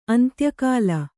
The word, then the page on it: ಅಂತ್ಯಕಾಲ: Kannada